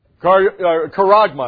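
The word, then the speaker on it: charagma: The Greek word is sharkama. Karagma, I should say. It's karagma. Better pronunciation.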